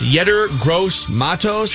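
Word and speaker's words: Yetur Gross-Matos: Is it pronounced Yetur Gross Matos? – Sure.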